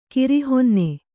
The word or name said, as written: ಕಿರಿಹೊನ್ನೆ